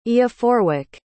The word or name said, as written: eoforwic